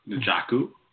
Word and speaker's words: Njoku: Njoku. – Njoku? Please say his name right.